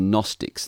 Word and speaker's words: Gnostics: And it came at a time when there was a group of teachers called the Gnostics, and that's a Gnostic with a capital G. In English, the G is silent, so I guess if we pronounced the G, it would sound like Gnostics.